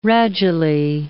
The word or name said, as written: radulae